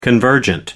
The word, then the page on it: convergent: American English